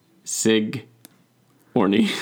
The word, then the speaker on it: Sigourney: Singorni Weaver? Get rid of the N in the beginning. Sig. Orny.